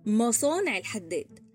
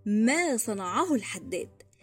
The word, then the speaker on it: ما صنعه الحداد: المشكلة بقى في نطقنا إحنا إحنا بنقول مصانع الحداد مع إن المثل ما صنعه الحداد